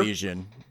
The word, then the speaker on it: Elysian: Elysian? Is that how you pronounce it? They're, Elysian. – Elysian? Yeah.